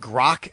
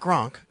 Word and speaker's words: Grok: Joe, do you know what Grok is? Not Gronk. Grok? Grok? Grok? How do you say it? – G-R-O-K?